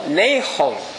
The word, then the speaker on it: Nahum: 我听一位犹太人说啊，这个英语啊是念作 Nahum，但是呢希伯来话跟中文啊很相近。